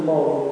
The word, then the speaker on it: Maugham: Somerset Maugham, si scrive così, si pronuncia Maugham, Somerset Maugham.